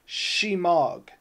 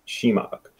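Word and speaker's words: shemagh: Um, so Christopher Lovelace, uh, he just wanted us to know it's pronounced. And I did I say this right, Chris? Is it Shemog? Shemog. Shemog. Yeah.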